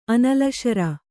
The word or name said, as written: ಅನಲಶರ